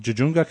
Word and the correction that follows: Tujunga: California? – Tujunga, California.